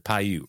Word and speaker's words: Paiute: But the real mystery that we're all here for, and I hope I say this right, Paiute, right? The Paiute Indians? – Sounds right to me.